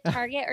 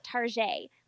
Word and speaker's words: Target: You can pronounce it Target or you can pronounce it Tarjay.